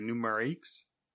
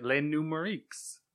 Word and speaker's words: Les Numériques: How do you pronounce that? – Les numéro 15. That's more Spanish, actually. – Numerics. Yeah, that's what I was thinking. Numériques. – Yes. – Les numériques. Yeah. French tech website, Les numériques.